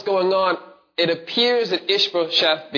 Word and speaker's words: Ishbosheth: I always mispronounce this guy's name. I feel really sad for Mephibosheth. No, that's a different word. Ishbosheth is made king. Yes, thank you for pronouncing that correctly.